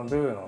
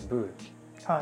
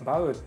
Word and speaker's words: VOU: これはあのブーの VOU と書いてブー、はい、バウっていうふうに読んでもいい